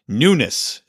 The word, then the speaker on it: Nunes: Look, I looked up how to say it. Nunes is how it's said.